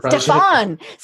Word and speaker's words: Stefan: By the way, I'll just let you know the pronunciation is Stefan. – Stefan. See, I got the last name right, didn't I? – You did, actually.